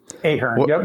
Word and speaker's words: Ahearn: Yeah, you got it pretty right. Ahearn.